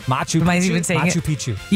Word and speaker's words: Machu Picchu: Machu Picchu. How do you say that? – Machu Picchu.